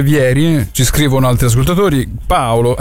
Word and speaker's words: Vieri: C'è un altro brasiliano storicamente importante che ha un cognome italiano e si pronuncia senza accento, quindi non è Vieri ma è Vieri, come non era Altafini ma Altafini, cioè José Altafini, cognome italiano.